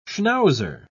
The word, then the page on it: schnauzer: British English